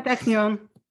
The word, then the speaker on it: Technion: Yeah, Technion.